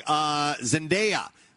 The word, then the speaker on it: Zendaya: Zendaya which I found that that is how you pronounce her. – Yes. – I've heard her say it herself.